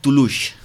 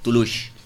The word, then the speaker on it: Toulouse: A la localitat de Toulouse. No Toulouse, Toulouse. No sé si ho pronuncio bé,